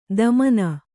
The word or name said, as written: ಧಮನಿ